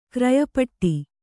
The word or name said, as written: ಕ್ರಯಪಟ್ಟಿ